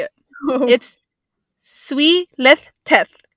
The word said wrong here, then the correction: Zwielichtig: Whoever has the lisp, I am so sorry. I can't say this word. But this is a way. Let me play it for you. – Zwielichtig. – Oh, wow. – Zwielichtig. – But the word is spelled Z-W-I-E-L-I-C. H-T-I-G. I know.